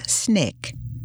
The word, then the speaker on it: SNCC: However, with Baker's assistance and encouragement, the students formed their own independent organization and named it the Student Nonviolent Coordinating Committee, SNCC, pronounced SNCC.